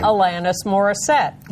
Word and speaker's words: Alanis Morissette: Alanis Morissette.